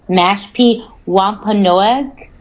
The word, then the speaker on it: Mashpee Wampanoag: forgive me if I say it wrong, Mashpee Wampanoag, an indigenous tribe who lived in the area for roughly like 10,000 years before the Mayflower even landed. – Hey, you know so, what? I can tell you that you did say that right.